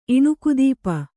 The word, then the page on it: ಇಣುಕುದೀಪ: Kannada